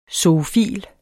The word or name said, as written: ZOOFIL